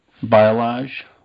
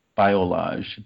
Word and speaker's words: Biolage: Biolage. – Biolage? – Biolage. B-I-O-L-A-G-E, I think it's spelled.